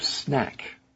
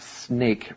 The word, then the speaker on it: snack: Then you will want to snack. Remember, friends, this is not snake. Remember to pronounce this correctly. Snack.